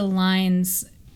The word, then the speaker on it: loins: If you read it in original pronunciation, the word loins becomes lines. From forth like the fatal lines, yeah. – which does sound like lines.